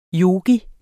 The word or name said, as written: yogi